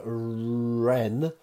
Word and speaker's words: wren: The British birds, the British bird that has the Latin name Troglodytes, Troglodytes, is the wren. Hard to say that W at the start.